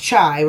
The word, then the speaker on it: Chai: I'm saying it right. It's not Shy. It's Chai, right? It's spelled C-H-A-I.